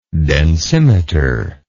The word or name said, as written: densimetry